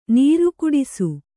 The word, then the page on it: ನೀರುಕುಡಿಸು: nīru kuḍisu